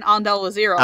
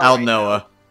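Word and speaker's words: Aldnoah.Zero: But I did say Elysium Vers Alusa in Undall Azero. – Aldnoa. – Right? Aldnoa.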